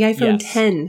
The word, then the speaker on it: iPhone X: The iPhone 10. Yes. The X is pronounced like 10.